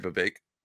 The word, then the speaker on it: Vivek: I'm sorry, Vivek. Rhymes with cake.